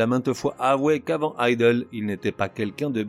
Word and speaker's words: Idles: Candy Gronaz, Idol, qu'on pourrait traduire par feignasse et qu'on prononce bizarrement comme idol, est le groupe de la résurgence punk par excellence 40 ans après.